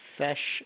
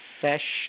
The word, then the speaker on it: Fesh: Robert Fesh, F as in Frank, E-S-H. – Spell that last name one more time, please. – Fesh, like fish without the I. It's an E instead. – F-E-S-H? – Yes, ma'am.